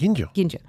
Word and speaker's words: Ginjo: How do you say it? – Ginjo.